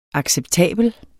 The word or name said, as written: acceptabel